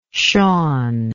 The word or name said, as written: Sean